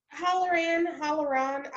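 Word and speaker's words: Hallorann: As I was editing, I realized that my drunk ass never pronounced uh, Hollerin correctly. Oh, I started saying Oh Hollerman and like all these things. So I'm sorry to all the O'Holloran oh, fans out there in the world. I'm saying it correctly now, correct? – Halloran, Halloran. I.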